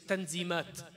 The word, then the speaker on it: Tanzimat: The Young Ottomans were actually not even satisfied with the Tanzimat, which you pronounce in Turkish as Tanzimat, which we spoke about last week.